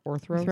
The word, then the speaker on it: Orthros: Yeah, is that how you pronounce that? Orthros. – Orthros, a two-headed monster.